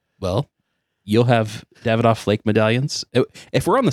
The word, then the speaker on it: Davidoff: So I say Davidoff a lot, and I know it's pronounced Davidoff. – You got called out for this. – I got called out about it one time on Instagram. So I just wanted to say, I know a minute ago I said Davidoff Flake Medallions, and I know it's Davidoff.